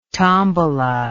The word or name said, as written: tombola